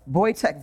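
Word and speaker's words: Wojtek: Voltec. That's what they named the Voitec. Sorry. Voitec. Voltec sounds like a... Voitec is the bear's name. It's spelled with the W.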